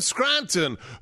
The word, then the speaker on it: Scranton: It's not a difficult word. It's got two syllables, scran-ton.